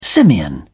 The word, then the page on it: simian: British English